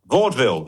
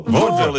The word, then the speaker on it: vaudeville: I've never been sure if it's Vaudeville or Vaudeville. – Vaudeville. Vaudeville is the correct pronunciation.